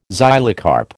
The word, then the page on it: xylocarp: American English